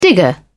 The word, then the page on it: digger: British English